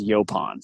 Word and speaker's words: Yaupon: But people say Yaupon, people say Yaupon, the list goes on and on, but the correct pronunciation is Yaupon.